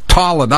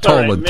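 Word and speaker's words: Tolland: Tallinn. I'm sorry, right, I mispronounced it. Tallinn, not Tolan.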